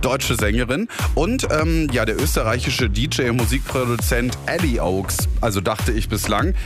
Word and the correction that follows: Eli Oakes: Er hat jetzt im Interview klargestellt, wird anders ausgesprochen, wird Eli Oakes ausgesprochen.